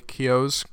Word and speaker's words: Keough: There's some really charming tributes to old Hollywood with eye lighting on our female character, Riley Keough's character.